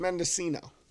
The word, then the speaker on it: Mendocino: Medicino Purps. – Mendocino? – Mendocino? Medicino? – Oh, we got Mendocino Greeno to smoke, too, coming up. – I think it's Mendocino.